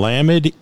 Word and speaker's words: Lamed: The Hebrew letter assigned is Lamed, and it is pronounced L as in love. And Lamed is the tallest letter in Hebrew.